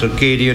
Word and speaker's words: Acadia: Instead, in a small area called in French, Acadie in English, that's pronounced Acadia, and they were happy there.